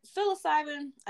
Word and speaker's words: psilocybin: Like back to the like psilocybin.